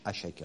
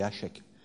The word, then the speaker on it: Hašek: Avec Hašek, on prononce Hašek, hein, H-A, et c'est le S avec un accent circonflexe retourné, donc c'est Hašek.